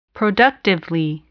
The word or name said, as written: productively